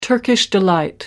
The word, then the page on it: turkish delight: American English